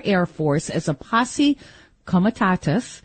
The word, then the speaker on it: posse comitatus: I'll give you the usual lawyer's pronunciation, which I think is the proper Latin. Not always lawyers, you know, mess up Latin because most lawyers are big dopes, as you know. It's posse comitatus.